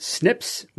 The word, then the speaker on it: SNPs: The other really important DNA marker that the researchers looked for were single nucleotide polymorphisms, SNPs, which are pronounced SNPs, which we've mentioned on the show before, including about 20 minutes ago.